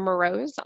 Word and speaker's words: Mroz: Um, you can find me by na- my name, Irina Mroz, on LinkedIn.